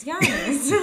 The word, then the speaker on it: Giannis: It's Giannis.